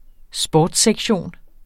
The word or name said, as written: sportssektion